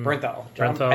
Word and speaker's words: Bernthal: You're okay with Bernthal's or however you say his name. – Bernthal. I believe it's John Bernthal.